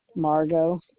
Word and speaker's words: Margo: Margo Raff. – Okay. Can you spell it, please? – M-A-R-G-O, and then my last name is Raff, R-A-F-F, as in Frank, Frank.